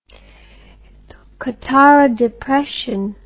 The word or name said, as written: Qattara Depression